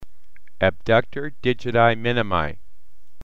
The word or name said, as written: Abductor Digiti Minimi